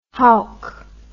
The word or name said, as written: howk